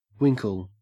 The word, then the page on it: winkle: Australian English